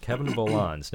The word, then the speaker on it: Kevin Volans: That was the String Quartet number 2, the second movement by Kevin Volans. Am I saying that right? – That's correct, yeah. – Kevin Volans.